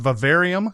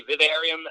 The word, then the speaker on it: Vivarium: Is Vivarium or Vivarium? – I don't really know. I think in the UK, in Ireland, people say Vivarium, and in the States, people say Vivarium.